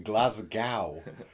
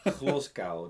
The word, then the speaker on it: Glasgow: Don't pronounce the W in Glasgow. – Glasgow, the Dutch.